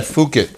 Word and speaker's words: Fouquet: You could pronounce that Phuket. Phuket. – Phuket. – Phuket. – It's like bouquet, but with an F.